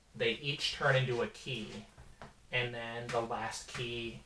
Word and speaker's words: Chi: So it's actually called Kingdom Hearts Key. It's spelled C-H-I, and it's pronounced key, and that's why when you hear they talk about the Keyblade, because, come on, Nomura!